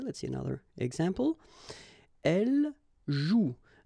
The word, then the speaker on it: joue: so you've got this after, but then clearly you don't pronounce it. Okay, elle joue. And then, je skie.